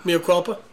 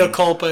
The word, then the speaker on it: mea culpa: Mikapa? – Mea culpa? – Yeah, whatever. – Okay. – You know what I mean. – Mea culpa is actually how you say it if you're using layman tongue.